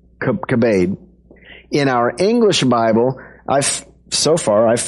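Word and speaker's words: kabed (כבד): Now, in the verb form, it's pronounced kabed. In the noun form, it's pronounced kabod, but it's the same word.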